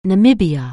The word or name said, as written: Namibia